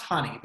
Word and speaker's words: HNNY: It's called HNNY. It's pronounced honey, but it's HNNY, Henny.